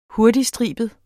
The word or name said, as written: hurtigstribet